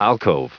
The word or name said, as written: alcove